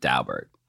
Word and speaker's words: Daubert: In 1993, the Supreme Court decided this decision called Daubert, you know, and I, even though it looks like it should be pronounced Daubert, I have no people who know the Dauberts and that's how they pronounce it.